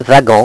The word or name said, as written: wagon